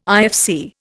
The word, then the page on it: IFC: Indian